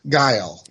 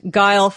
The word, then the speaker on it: Guile: And how do I pronounce that? – Guile. – Guile. Okay, so like it looks.